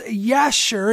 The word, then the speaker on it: Jasher: Again, this is found in the book of Jasher. It's pronounced Yasher in Hebrew, and this is a book of Jewish history.